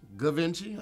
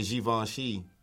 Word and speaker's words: Givenchy: The word is G-I-V-E-N-C-H-Y. – Ah, that's another one that we've heard said wrong and right. Mm-hmm. I've heard it pronounced Givenchy.